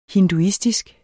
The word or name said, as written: hinduistisk